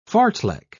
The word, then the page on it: fartlek: British English